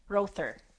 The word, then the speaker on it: Rother: I want to make sure I say Rother.